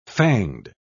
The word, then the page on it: fanged: British English